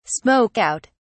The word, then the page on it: smoke out: British English